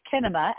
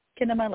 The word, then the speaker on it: Kinema: And I'll adjust the pronunciation a little bit as we pronounce it Kinema, as it rhymes with cinema, which is we found when we were naming ourselves during the pandemic, we didn't say our name aloud a lot.